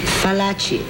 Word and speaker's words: Fallaci: Fallaci, F like flower. Not V like Venice.